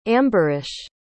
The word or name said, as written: ambarish